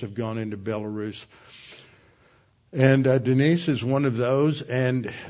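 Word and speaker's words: Denis: It's D-E-N-I-S, but it's pronounced Denise, not Dennis.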